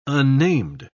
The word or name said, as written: unnamed